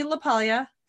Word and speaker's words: LaPaglia: which I had to look up how to say his last name because I've read it a million times and I never knew how to say it. It's Anthony LaPaglia.